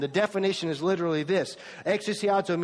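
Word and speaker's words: Exousiazo (ἐξουσιάζω): That word, mastered, it's a Greek word, and it's pronounced like this. See if you can say it with me. Exousiazo. The Greek word is exousiazo.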